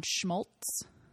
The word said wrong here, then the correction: Smoltz: Smoltz. No schmr.